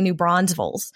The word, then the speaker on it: New Braunfels: It is German, but we uh, nobody I know pronounces it that way. We say New Braunfels, and there's no extra S in the middle, and that's the title of this one.